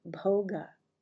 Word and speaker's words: bhoga: The pursuit of pleasure is called bhoga, which rhymes with yoga.